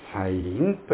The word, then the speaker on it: 再臨: 再びという字に望むと書いて再臨と読みます。このアドベントという言葉にキリストの再臨という意味があることを聞くと、おそらく一般の人々は驚くことでしょう。なぜかと申しますと、一般の人々にとってはキリストの再臨と最後の審判の教えはとても現実離れしたことに思えるからです。